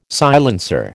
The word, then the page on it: silencer: American English